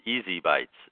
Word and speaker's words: ESI Bytes: You can also remember our show and website phonetically. It's Easy Bytes, is what it sounds like, of ESI information.